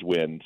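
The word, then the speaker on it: Nguyen: And Winners is spelled like the very common Vietnamese last name. Nguyner. It's pronounced Wynn.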